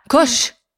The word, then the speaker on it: Koch: Kosh. Kok. Kosh. K O C H. – Ja, Kosh.